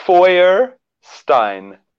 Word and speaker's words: Feuerstein: Beautiful definition from this book, Sacred Sexuality by George Feuerstein. How do you say it? – Jai Jagannath, I'm gonna tell you this one more time, right? Okay, goes like this. Feuerstein. – Feuerstein. Oh, that's right.